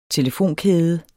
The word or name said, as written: telefonkæde